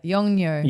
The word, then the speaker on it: Yongnuo: Next one up, the company Yongnuo, I pronounce it correctly, obviously, yeah? – Yeah, Yongnuo.